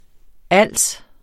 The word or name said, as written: ALS